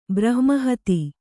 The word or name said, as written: ಬ್ರಹ್ಮಹತಿ